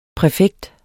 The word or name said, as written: præfekt